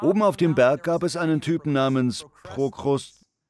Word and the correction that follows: Prokrustes: Ich kann den Namen nicht richtig aussprechen. Oben auf dem Berg gab es einen Mann namens Prokrustes.